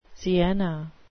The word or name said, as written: sienna